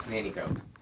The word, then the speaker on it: Nanticoke: Nanticoke.